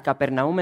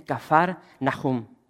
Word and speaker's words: Kafar Nahum: Y hermanos, se nos nombra esta ciudad, la ciudad de Capernaum en sí, la pronunciamos de esa manera, pero es Kafar, así con F, Kafar Nahum. Cuando vas a Israel, como que se te pega un poco el acento de los judíos, escupen mucho. Y es la forma correcta de pronunciar este nombre, Kafar Nahum.